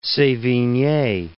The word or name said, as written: Sevigne